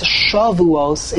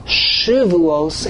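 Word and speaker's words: Shavuos (שבועות): When we pronounce it with a Komatz, Shavuos, it means weeks. When we pronounce it with a Shiva, Shavuos, it means oaths.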